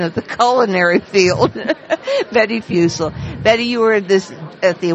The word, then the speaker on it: Fussell: I called her Betty Fussell, and in fact, it is Fussell rhyming with Russell.